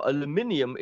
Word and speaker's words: aluminium: They make aluminum, or aluminium is the correct way to say that.